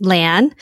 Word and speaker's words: Lan: It's Lan.